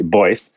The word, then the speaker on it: Beuss: And there's a name there that's spelled B-E-U-S-S, like, and it's pronounced like in German you say Boyce.